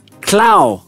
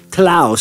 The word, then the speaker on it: TLOU: Now, the ticker is TLOU, but it's pronounced Clow, as in cloud, without a D, apparently, in Botswana. But you'd say it Clow.